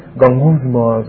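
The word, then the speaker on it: gongousmas (γογγυσμός): By pronouncing the word, it raises the image of what is happening. The idea is murmuring, complaining, and say, gongousmas, gongousmas, gongousmas.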